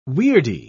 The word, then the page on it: weirdie: British English